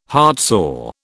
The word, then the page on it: heartsore: British English